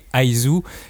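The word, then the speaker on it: Is: Et l'auteur voulait pas ice parce que ça faisait trop penser à la glace, qui est le contraire de, son manga qui évoque la chaleur et l'amour. Ah. – Du coup, il faut vraiment prononcer Aizu. Et Ai d'ailleurs fait aussi penser à amour en japonais, etc.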